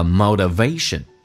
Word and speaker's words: motivation: And the motivation that you need， motivation 在美式发音当中跟英式发音有很大的区别，它发成 motivation，那个 t 去向一发 d，这个发音叫 motivation，重音在 v 后面， motivation 这样的一个动力。